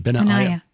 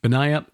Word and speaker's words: Benaiah: Benaiah. – I had it right the first time. – Mm-hmm. – Sweet. – Just think of that AI as a diphthong. – Okay. Benaiah, or the mighty men, or Solomon, his brother.